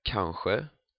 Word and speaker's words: kanske: We have a sound in Swedish called the ske sound. In the word kanske, there is this ske sound. It's from what I heard, it's pretty hard to pronounce for non-Swedish speakers.